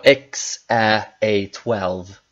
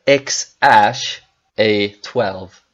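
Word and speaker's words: X Æ A-12: Och ni kommer ihåg att det var X, Ä, A12. – Ja. – Men, men enligt Elon så, så ska man uh, uttala det X, Ash, A12. – Så det här ät blir ash.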